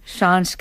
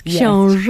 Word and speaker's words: Książ: Oho, no tak, na początku naszej rozmowy właśnie zapytała Marisa o wystawę w Wałbrzychu w Pałacu Książ i... – I can't pronounce it so well. Książ Castle? Książ Castle? – Yes, Książ. – Yes.